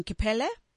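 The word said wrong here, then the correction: Kpelle: It's called Fele. Yeah, Fele.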